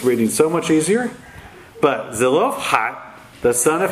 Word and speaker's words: Zelophehad: So it's pronounced Zelophehad. You have to do that ha thing at the end. Doesn't come out naturally to me. So it's Zelophehad, however that would come out.